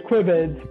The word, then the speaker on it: Quibids: Yes, the way it was supposed to be pronounced was Quibids. – Quibids. – Which is like quick bids right.